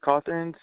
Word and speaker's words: Cothern: Chris Cawthorn, CHRISCOTHERN.